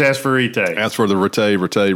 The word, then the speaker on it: Retay: I did find out the correct pronunciation is Retay.